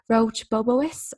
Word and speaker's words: Roche Bobois: I suppose, especially, you know, I've been into like, I'm definitely going to butcher this, the Roche Bobois or? – Yeah. – Yes. Oh, there you go.